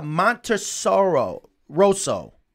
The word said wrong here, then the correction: Monterroso: I butchered it, didn't I? – Close. Monterosso. – Monterosso. – A lot of people say Montessoro, and some people say Monterasso. So.